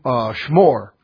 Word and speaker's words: shmor: It comes from a Hebrew root word, shmor. Shmor, yeah, like the chocolate marshmallow and graham cracker.